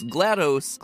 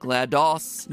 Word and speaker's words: GLaDOS: As far as how you pronounce GLaDOS, GLaDOS, GLaDOS, apparently is a viable option.